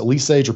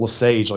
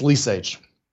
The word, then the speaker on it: Lesage: The only people who pronounce it Lesage are people from there. Everybody else pronounces it Lesage, like some fancy French shit. It ain't, it's Lesage.